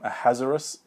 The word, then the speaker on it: Ahasuerus: She is um, the the king um, um, Ahasuerus um, is often how you pronounce it.